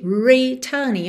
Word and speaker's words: returning: Essa palavra returning é bem interessante para você reparar que em algumas situações, o R precisa ser enrolado, como aí na primeira sílaba de returning. Eu não tenho uma vogal antes do R para ele prolongar essa vogal, ok? Nesses casos, eu realmente enrolo a língua. Então, returning.